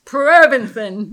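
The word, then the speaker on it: Provensen: Provinson with long O.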